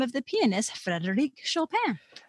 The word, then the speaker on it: Chopin: So don't say the N, it's Anne. – Okay. – Chopin. And I know that's hard for non-native speakers to make that sound, but that's the sound.